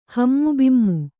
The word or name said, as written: ಹಮ್ಮುಬಿಮ್ಮು